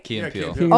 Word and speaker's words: Key and Peele: Yeah, Key and Peele.